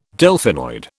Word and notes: Delphinoid: British English

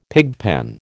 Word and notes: pigpen: American English

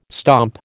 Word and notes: stomp: American English

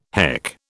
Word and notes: heck: British English

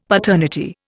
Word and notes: paternity: Indian

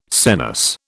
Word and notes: senesce: British English

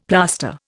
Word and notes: plaster: Indian

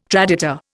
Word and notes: traditor: Indian

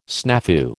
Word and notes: snafu: American English

